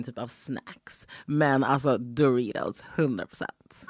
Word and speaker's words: Doritos: Du uttalar Doritos på amerikanska. Doritos, helt enkelt.